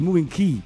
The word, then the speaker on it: Moving K: E allora, visto che il gruppo è un altro gruppo, Moving K o Moving Key per l'esattezza, visto che la K in inglese ha questo tipo di pronuncia, tra poco parleremo con loro e parlo con il leader del gruppo.